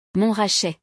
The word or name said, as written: Montrachet